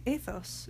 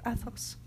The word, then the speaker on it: Athos: You know, some people might not realize, but you actually pronounce the name Athos instead of Athos. Yeah. Because Athos sounds like you're saying asshole.